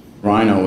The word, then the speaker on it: Ryno: Rhino was an acronym. It's spelled R-Y-N-O.